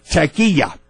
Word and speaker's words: Taqiyya: The word is, I'll spell it. You all can look it up for yourself. T-A-Q-I-Y-Y-A. Taqiyya. Taqiyya. I broke it down. It's like tack, and the emphasis is on the K.